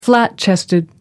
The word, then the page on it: flat chested: American English